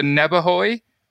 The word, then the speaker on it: Nebehaj: And Victor Neberhoy, have I said your name right? – That's correct.